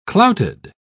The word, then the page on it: clouted: British English